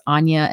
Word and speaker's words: Anya: That's Anya and then 3 underscores Quinn, Quinn.